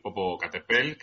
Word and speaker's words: Popocatépetl: Pues creo que te refieres al gran volcán Popocatépetl de, no sé si lo he dicho bien. – Sí, sí, sí. Sí, sí, es el, volcán, el Popocatépetl, que está en México y, bueno, y es como, digamos cariñosamente, es como le llaman allí al, volcán.